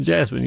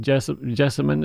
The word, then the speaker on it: jessamine: And so it's not, well, you don't pronounce it jasmine. You spell it J-E-S-S-A-M-I-N. Some people call it jessamine, but jessamine is what I say.